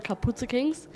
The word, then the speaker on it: Kapuze Kings: Ach, ohne, ja, genau, ja, das haben wir ganz falsch gesagt, das müssen wir nochmal sagen. Nicht, Kapuze? – Das ist, hier ist auch ein Leerzeichen, aber das ist eigentlich zusammengeschrieben und das heißt nur Kapuze Kings.